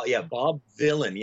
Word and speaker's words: Vylan: Bob Villain. Yeah. So it looks like and it's pronounced like Bob Dylan, but it's Bob Villain with a Y.